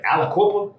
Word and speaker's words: Aliquippa: Aliquippa? – That's it.